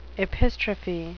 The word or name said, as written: epistrophe